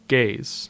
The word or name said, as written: Gays